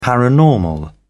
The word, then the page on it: paranormal: British English